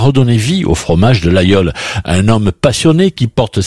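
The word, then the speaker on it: Laguiole: Ça s'écrit l'Aguiole, mais on prononce l'Aïeul.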